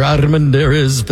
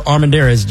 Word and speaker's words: Armendariz: I am Victor Armendariz, filling in for Eric Erickson. Listen, folks, you can find me, Victor Armendariz. Just spell it correct, A-R-M-E-N-D-A-R-I-Z.